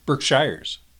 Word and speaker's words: Berkshire: Berkshires. How do you pronounce it? – Berkshires. It's Berkshire. Haven't you ever heard of like the most valuable company, Berkshire Hathaway? – I think it's Berkshire.